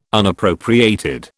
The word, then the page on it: Unappropriated: British English